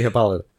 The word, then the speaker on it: Hippolyta: But it's definitely Hippolyta.